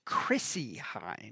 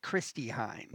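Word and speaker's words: Chrissie Hynde: It is Chrissy Hynde, right. – Not Christy Hynde.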